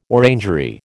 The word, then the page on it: orangery: American English